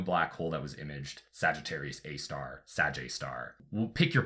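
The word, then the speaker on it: Sagittarius A*: I'm glad you figured out how to pronounce it because I kept seeing it like Sagittarius A asterisk. That's a mouthful.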